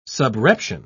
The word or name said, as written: subreption